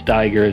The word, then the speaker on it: Steiger: So that's A-N-D-Y, and then Steiger is spelled S-T-E-I-G-E-R.